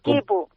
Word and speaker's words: Kipu: Piña, workshop, Nipu, no sé si lo digo bien. – Kipu. – ¿Cómo es Kipu? – Kipu, sí.